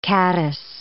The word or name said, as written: caddis